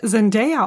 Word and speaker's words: Zendaya: Fun Fact, ne, ihr Name wird eigentlich, der wird Zendaya ausgesprochen. Ich dachte früher auch immer, man sagt Zendaya und dann habe ich mal so einen Livestream mit ihr geguckt und dann hat sie jemand gefragt, wie ihr Name ausgesprochen wird und sie dann so, ja, Zendaya, weil so kreativ war meine Mutter nicht, dass ich jetzt Zendaya heiße.